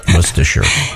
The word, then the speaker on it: Worcestershire: I never pronounced that right. Worcestershire. I used to call it Worcestershire because that's what it looked.